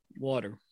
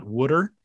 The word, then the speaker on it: water: Water. – Thank you. You're a very normal pronouncing Philadelphia area native. It's not Wooder. You got it correct.